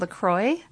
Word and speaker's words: LaCroix: It's called LaCroix. But it's spelled differently than that.